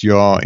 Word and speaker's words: Yah: You can transliterate it differently in different languages, but fundamentally it is Yah, and it is pronounced Yah in all languages.